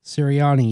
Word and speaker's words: Nick Sirianni: The Eagles are expected to hire the Colts offensive coordinator, and the number one priority for this new guy, Nick Sirianni. Is that how you pronounce his name? – Sirianni, yes.